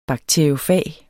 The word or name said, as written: bakteriofag